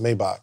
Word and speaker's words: Maybach: It's actually pronounced Maybach. – I know it's Maybach, but for me, it's Maybach.